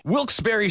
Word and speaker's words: Wilkes-Barre: Now, I had heard that it was supposed to be pronounced Wilkes-Barre. Wilkes-Barre Scranton's 97.9X. The only way to pronounce Wilkes-Barre.